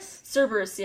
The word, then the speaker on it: Cerberus: Cerberus, yeah.